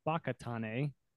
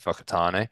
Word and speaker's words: Whakatane: So yeah, you mentioned it a little bit, but you're from I've learned the correct pronunciation, Whakatane, although it starts with a W, Whakatane, New Zealand.